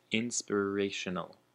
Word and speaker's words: inspirational: And the last word is inspirational, which also has the emphasis on the re, inspirational.